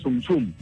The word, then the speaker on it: Zum Zum: Este intérprete se llama Muñeca Acosta, pero la comparsa es Zum Zum, Zum así Zum. Como suena nada más.